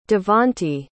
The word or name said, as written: davonte